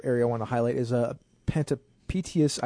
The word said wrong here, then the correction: Panopticus: I can't actually pronounce that word. I probably should have looked it up. – Panopticus. – Panopticus, thank you.